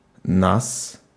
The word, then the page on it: oss: /ɔs/